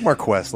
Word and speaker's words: Marquess: Marquis? – I thought Marquis, too. The Mad Marquis. – How do you spell it? M-A-R-Q-I-S? – Q-U-E-S-S. The Mad Marquis. – I like Marquess. Let's roll with that.